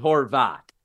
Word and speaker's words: Horvat: So we pronounce it Horvat.